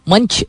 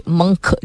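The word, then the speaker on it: Munch: मंच मंक जिस तरह भी आप प्रोनाउंस करें उनका नाम डिफरेंट तरीके से डिफरेंट लोग करते हैं M U N C H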